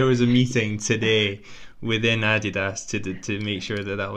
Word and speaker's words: Adidas: So, interestingly enough, we had like a major brand strategy reveal this morning, and everyone that's German will say Adidas, and everyone that's American will say Adidas. The correct pronunciation is Adidas because it's from Adi Dassler, which is the founder.